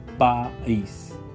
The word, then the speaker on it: país: And the last word is the word, the Spanish word for country, and the word in Spanish is spelled like P-A-I-S, and this word is pronounced like país, país. Okay, so it's independent as well, A-I, each vowel is independent, and we have to pronounce each one, A-I, so it'd be país.